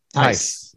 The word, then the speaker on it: Theiss: Theiss.